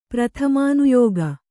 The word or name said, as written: ಪ್ರಥಮಾನುಯೋಗ